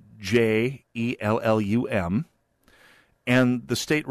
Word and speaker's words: Jellum: And you can guess that they were both pronounced differently. Oh yeah, one of them was Jellum, and the other one was Yelly and they're both different corruptions from the original Scandinavian, uh, but they had their preferred corruption.